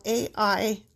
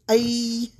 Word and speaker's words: Ai: I want to say it just like that. It's spelled A-I, Ai.